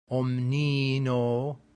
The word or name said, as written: omnino